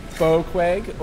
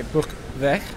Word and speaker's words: Boekweg: Boekweg, or if you're Dutch, you could say Boekweg.